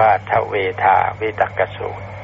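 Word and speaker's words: เทฺวธาวิตกฺกสูตร: จะเป็นเทวทาวิตกสูตรแต่ว่าไม่ใช่นะครับต้องอ่านว่าทเวทาวิตกสูตรถ้าท่านไปเห็นตัวเขียนท่านอาจจะอ่านเป็นเทวทาแต่คำอ่านต้องอ่านว่าทเวทาวิตกสูตร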